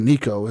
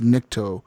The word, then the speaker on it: Nikto: I don't know how you pronounce his name. It's like Nikto. Nikto. It was called the Nikto operator, because his name is not Nico. His name is Nikto, and he's an operator.